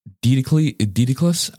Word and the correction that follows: Daedalus: I totally don't know how to pronounce his name. – I think it's Daedalus. – Daedalus and Icarus from Greek mythology.